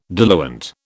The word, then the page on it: diluent: British English